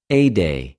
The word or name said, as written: ἠδη